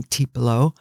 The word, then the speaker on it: Tiepolo: And for those of you that care, in English, most people pronounce his name Tiepolo, but, you know, famous Italian painter.